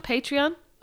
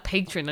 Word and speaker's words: Patreon: Patreon? – Oh, look, the saga continues. It's... I've been saying it wrong. – It's definitely not patron anyway.